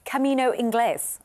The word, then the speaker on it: Camino Inglés: But have you heard of the Camino Inglés?